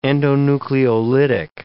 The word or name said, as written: endonucleolytic